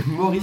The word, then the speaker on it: Moorrees: Alors oui, Maurice, ok, ça sonne facilement français, mais ça s'écrit quand même avec deux O, deux R et deux E. – Maurice. – C'est Maurice. Maurice. C'est M-O-O-R-R-E-E-S.